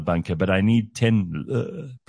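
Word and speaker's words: leu: Nah, the lur.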